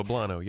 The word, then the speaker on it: poblano: Uh, am I saying it right? Poblano, right? – Poblano, yes.